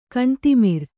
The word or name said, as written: ಕಣ್ತಿಮಿರ್